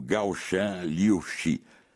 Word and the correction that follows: Gaoshan Liu Shui: A pronúncia está correta? Pode corrigir. Eu desconheço o mandarim, por favor. – Quase. Liu Shui. Gaoshan Liu Shui. – Ah, Gaoshan Liu Shui.